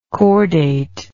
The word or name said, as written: cordately